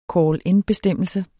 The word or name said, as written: call-in-bestemmelse